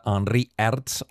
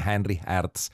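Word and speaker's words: Henri Herz: Bartra, la variació de Henri Hertz, o si ho voleu pronunciar en alemany seria alguna cosa així com Henry Hertz.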